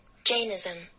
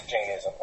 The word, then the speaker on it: Jainism: Jainism. – So it is Jainism. Okay. – And Jainism.